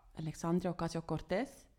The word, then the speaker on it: Alexandria Ocasio-Cortez: Weltweit ähm, Alessandra Alexandria Ocasio-Cortez, genau so spricht man es aus, und in der Stadt Bern Bernadette Häfliger.